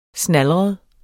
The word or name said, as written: snalret